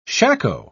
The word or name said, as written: shako